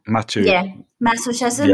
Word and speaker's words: masochism: S and M are sadism and I can't pronounce this. – Masochism. Is it? – Masochism. Yep. M-A-S-O-C-H-I-S-M.